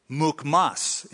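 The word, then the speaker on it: Mukhmas: It is Mukmas is the modern pronunciation.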